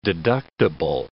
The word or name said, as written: deductible